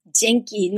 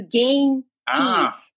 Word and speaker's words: Genki: So I explained them and asked my friend, how do I say Genki, not Genki, it's hard G.